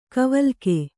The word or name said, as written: ಗಾವಿಲಿಕೆ